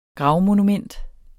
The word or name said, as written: gravmonument